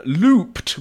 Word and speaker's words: Loopt: Looped, which...